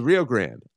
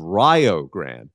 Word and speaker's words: Rio Grande: And I love it because it's not pronounced the way everyone else in the everyone's shocked this school even exists, which is unfortunately, and no disrespect, is a trend in NAIA when you hear about the school names. But it's the University of Rio Grande, but it's spelled like what most people say, Rio Grande.